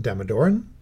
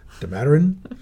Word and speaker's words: Damodaran: Well, one of my favorite guys is this wonderful guy over at NYU, Professor, I'm going to say his name wrong, Damodaran. – Okay. Hopefully I've got it right. I, no I say it Damodaran, Damodaran.